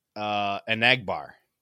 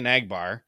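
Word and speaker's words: Enagbare: He told us how to pronounce his name. It's Anagbar. Kingsley oh. Anagbar.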